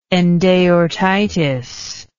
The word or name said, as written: endaortitis